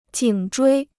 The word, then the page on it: 颈椎: jǐng zhuī